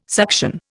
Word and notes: suction: Indian

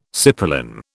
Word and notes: cipolin: British English

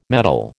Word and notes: mettle: American English

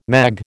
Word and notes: mag: American English

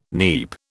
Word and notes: neap: British English

